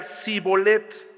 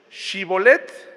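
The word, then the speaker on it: shibboleth: Si decía el hombre, no, no lo soy, ellos le pedían que pronunciara la palabra Shibolet. Si era de Efraín, diría Sibolet, porque a la gente de Efraín le cuesta pronunciar bien esa palabra.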